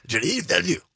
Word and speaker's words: Denis Villeneuve: That's from the soundtrack of Dune, the movie by, I'm probably going to butcher this name because he's French-Canadian, directed by Denis Villeneuve, something like that. – You have to say it quickly with a lot of throat and tongue. Denis Villeneuve, like that.